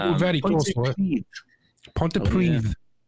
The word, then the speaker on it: Pontypridd: Is it Pontypridd? No. – Nearly. The two D's at the end is Pontypridd. It's a TH.